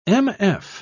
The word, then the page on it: mf: British English